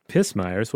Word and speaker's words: pismire: Pismires? What's a pismire? – Well, I did not know this word before. Pismires means ants. And Yes, it is pronounced pismire.